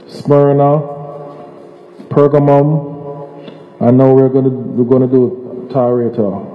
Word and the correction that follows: Thyatira: How you pronounce it? Yeah, the church of the T. Thyatira, yes, Thyatira, yes.